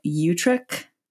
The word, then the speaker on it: Utrecht: They currently live in Utrecht, the Netherlands. Did I say that right? – Yes. – You got a little extra phlegm in there, too. That was great. Utrecht. – Yeah. Utrecht. Utrecht. Right. Perfect.